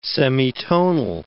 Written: semitonal

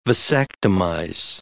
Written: vasectomize

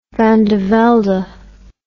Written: van de velde

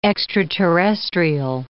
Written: extraterrestrial

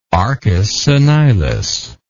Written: arcus senilis

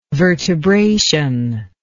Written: vertebration

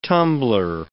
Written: tumbler

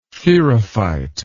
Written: therophyte

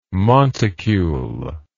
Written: monticule

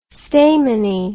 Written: stamini

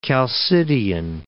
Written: Chalcidian

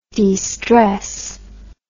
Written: destress

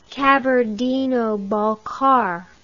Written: Kabardino Balkar

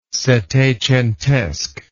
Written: settecentesque

